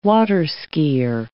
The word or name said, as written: water-repellent